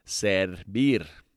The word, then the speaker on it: servir: The verb of the day today is servir.